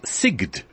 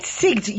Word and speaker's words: Sigd: about SIGD. – Right, SIGD, you said it properly. I was saying it wrong for years. I was saying SIG, because I'm from New York.